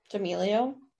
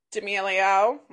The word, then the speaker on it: D'Amelio: D'Amelio. – D'Amelio.